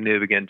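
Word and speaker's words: Newbigin: His name is Leslie Newbegin, just like you mentioned.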